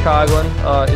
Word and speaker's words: Coughlin: I am Seamus McNamara Coughlin. Seamus okay. Coughlin is the proper pronunciation.